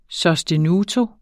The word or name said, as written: sostenuto